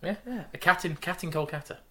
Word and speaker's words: Kolkata: Is it Calcutta? Yes. He's been sent to? – No, no, it's Kolkata. – Yeah, nice fact. Calcutta is actually Kolkata, and we've been saying it wrong for ages.